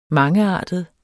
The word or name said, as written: mangeartet